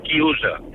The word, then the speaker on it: chiusa: Y un momento, y quiero decirle a Fernando que en italiano se dice chiusa. Coro a boca chiusa.